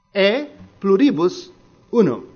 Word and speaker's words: E pluribus unum: But I'll tell you how it's pronounced because this is Latin. It's not English. E pluribus unum. E pluribus unum. That's how it's pronounced because it's Latin.